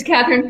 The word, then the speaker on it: Kathryn: It's Kathryn Fantauzzi.